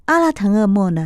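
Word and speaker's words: 阿 拉 腾 厄 莫 (Алтан эмээл): 金色的马鞍，蒙文的发音是阿拉腾厄莫呢，在蒙古文化里是一种幸福和理想的象征。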